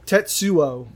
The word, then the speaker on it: Tetsuo: Tetsuo.